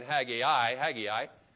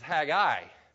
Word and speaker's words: Haggai: Now, if you grew up like I did and you started and you were saying Haggai, that's fine. I don't care. But I actually learned the way to pronounce this is Haggai. But if I say Haggai, it's because I'm going back to my old days and I forgot how it's supposed to be said, but it doesn't matter.